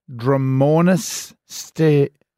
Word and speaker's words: Dromornis stirtoni: Well, archaeologists have discovered a set of female legs, like articulated legs belonging to a prehistoric Dromornis stirtoni. – Correct pronunciation on that one.